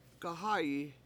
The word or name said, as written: gahA7yi